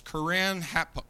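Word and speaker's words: Keren-happuch: It's Corinne Hapuk. And that's a K on the end of it, C-H, but it's pronounced with a K.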